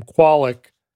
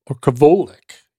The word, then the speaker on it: Kwolek: And let me just say that I am pronouncing her name Qualic, but it's a Polish name with a W.